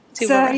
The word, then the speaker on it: Tuva: Yeah, Tuva, Russia.